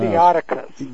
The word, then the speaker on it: Theotokos: Theotokos.